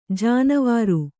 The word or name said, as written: ಜೇನವರೆ